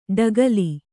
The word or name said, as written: ಡಗಲೆ